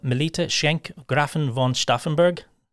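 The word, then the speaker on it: Melitta Schenk Gräfin von Stauffenberg: And then we have Melita Schenk Grafen von Stauffenberg. I think I got that right, did I? – Very well pronounced. Yeah, that's right. Yeah.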